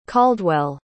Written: caldwell

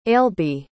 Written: ailbhe

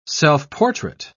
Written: self-portrait

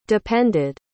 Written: depended

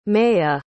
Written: maiya

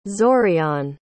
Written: zorion

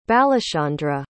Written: balachandra

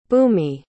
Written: bhoomi

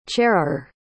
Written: chairer

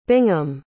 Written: bingham

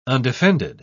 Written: undefended

